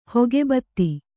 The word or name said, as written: ಹೊಗೆಬತ್ತಿ